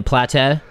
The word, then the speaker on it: plateau: Do you mean a plateau? – Is that not what I said? – You said plateau. So the way that you're putting the wrong emphasis on the wrong syllable.